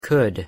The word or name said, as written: could